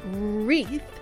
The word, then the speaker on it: wreath: Así que por favor, la palabra wreath se escribe de esta manera. Quiero que la escribas porque verdaderamente se escribe de lo que diferente a lo que se oye, ¿verdad, Armando? – Sí, sí, sí.